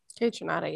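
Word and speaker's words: Kaytranada: Joyce Rice and how do you say that man's name? K. Trinata? – Kay Trinata, yeah.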